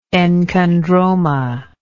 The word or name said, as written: enchondromatous